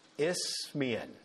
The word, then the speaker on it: Isthmian: Isthmian, there we go.